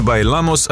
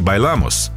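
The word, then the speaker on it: Bailamos: Забавно, что авторы «Байламос», британцы, вообще не говорили по-испански и неправильно расставили ударение. В слове «байламос» оно должно падать на второй слог, а не на последний.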